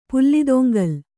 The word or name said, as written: ಪುಲ್ಲಿದೊಂಗಲ್